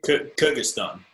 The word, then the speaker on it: Kyrgyzstan: Stuck in Chernobyl, artillery shelled in Kyrgyzstan. Did I pronounce that right? – Kyrgyzstan.